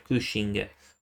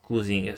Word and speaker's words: Cushing: Mm, come attore abbiamo il grandissimo Christopher Lee che ha fatto sette volte Dracula, Peter Cushing, o Cushing scusate per la pronuncia, sei volte ha fatto Frankenstein e cinque volte Abraham Van Helsing.